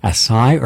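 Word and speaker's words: açaí: Is it A-C-A-I? Is it Acai or Acai? Both are correct. Depends upon if you're where it's grown, it's one pronunciation, but either one's going to work.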